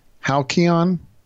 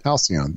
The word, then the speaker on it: halcyon: Halcyon. – You got it. – Halcyon.